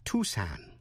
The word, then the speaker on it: Tucson: Tên tiểu bang A-R-K-A-N-S-A-S, có âm S câm, đọc là Arkansas. Arkansas, chứ không đọc là Arkansas. Thủ phủ, tiểu bang Arizona, có âm C câm, đọc là Tucson, chứ không đọc là Tucson.